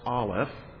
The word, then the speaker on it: Aleph: The first one is pronounced Aleph, the first letter of the Hebrew alphabet.